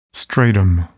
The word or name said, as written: stratum